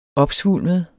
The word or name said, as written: opsvulmet